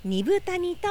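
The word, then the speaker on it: 二風谷: 2 時間、里乃さん、原田くん、ラジオ講座を支えてくださっている関根健二さんが住んでいるアイヌの里ビラトリ町二部谷にやってきました。豊かな自然、緑の森に囲まれ、猿川が流れる中、アイヌの伝統が色濃く残る地域です。漢字では 2 つの風の谷と書いて二部谷と読みます。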